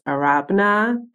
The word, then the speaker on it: Arabena: My Instagram is _ A-R-A-B-E-N-A. If you're wondering, it's pronounced Arabna.